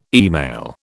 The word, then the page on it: email: British English